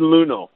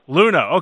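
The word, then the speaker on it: Luneau: Is it Tristan Luneo? – Tristan Luno. – Luno, okay.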